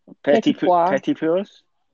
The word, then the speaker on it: petit pois: Is it peas poyos? – Petit pois is now British fancy peas, which sounds.